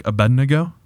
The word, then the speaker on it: Abednego: Shadrach, Meshach, Abednego. Did I say that right? You said it right.